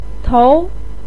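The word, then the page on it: tou: tone 2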